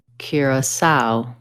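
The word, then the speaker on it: Curaçao: Curacao. – To od razu wyjaśnijmy. – Curacao. – Curacao. Tak się właśnie wymawia.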